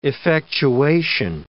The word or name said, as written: effectuation